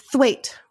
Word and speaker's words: Thwaite: Thwaite rhymes with weight.